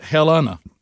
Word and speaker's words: Helena: Selena is a name. That's just like Helena, spelled just like it. So that mm-hmm. takes that out, or it'd be Selena. Arkansas says they pronounce it Helena.